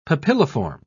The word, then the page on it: papilliform: British English